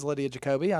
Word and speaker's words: Jacoby: Lydia Jacoby? I might be saying that wrong. The 17-year-old from Alaska. I watched her have that really cool moment. That was awesome. That was it is Lydia Jacoby, I know.